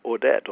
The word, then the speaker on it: Ordet: Ordet, or Urt, as I tried to pronounce it in Danish, actually sort of has a kind of miracle in it.